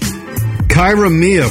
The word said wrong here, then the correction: Kiara Mia: 41. Look her up on your... – Kiara Mia, right? Spell it. – K-I-A-R-A. – Kiara, right? – Kiara Mia.